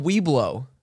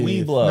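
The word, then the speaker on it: Webelo: They're called Weeblow. – No. – No. – Weeblow. – No way. – No, it's spelled like W-E-B-L-O. – But it said like...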